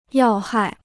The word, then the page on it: 要害: yào hài